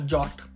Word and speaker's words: JWT: With something like a JOT, which is spelled as JWT or JSON Web Token.